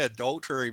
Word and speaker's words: adultery: I don't know if I said adulter. I kept saying, I couldn't say adultery before. I kept mispronouncing it.